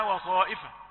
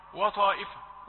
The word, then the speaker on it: وطائفة: عند كلمة وطائفة وطاء، واو رقيقة طاء مفخمة، يمكن أن تسمعها وطائفة وطائفة وطائفة،